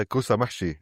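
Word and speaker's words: kousa mahshi: Who participated in our Bring a Plate video series last year, where he introduced us to kausamashi. Am I saying that right? – Yeah, kausamashi.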